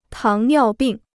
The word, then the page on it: 糖尿病: táng niào bìng